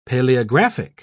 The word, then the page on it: paleographic: British English